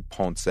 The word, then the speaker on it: Ponce: I'm at Anthony Ponce. My last name for any new listeners here is spelled P-O-N-C-E.